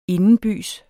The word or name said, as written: indenbys